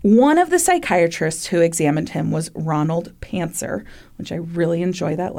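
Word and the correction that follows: Pancner: Actually, I think it's Pantzner, but it's spelled with a C P A N C N E R, Pantzner.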